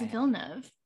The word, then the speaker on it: Villeneuve: No, it's Villeneuve.